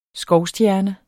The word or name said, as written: skovstjerne